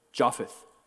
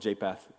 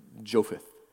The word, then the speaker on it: Japheth: Japheth is how you say it. The actual, I used to say called Japheth, but it's Japheth. Is the proper way to say Shem, Ham, and or Sham, Ham, and Japheth.